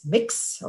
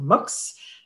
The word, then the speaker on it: Mx: And you may have seen the term MX, mix or mux,